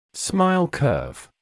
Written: smile curve

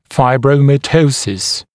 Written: fibromatosis

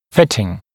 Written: fitting

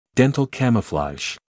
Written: dental camouflage

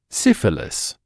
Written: syphilis

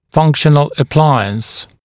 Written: functional appliance

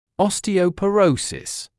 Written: osteoporosis

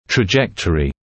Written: trajectory

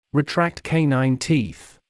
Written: retract canine teeth

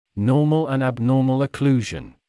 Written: normal and abnormal occlusion

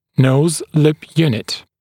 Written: nose-lip unit

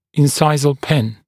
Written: incisal pin

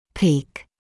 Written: peak

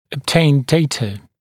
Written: obtain data

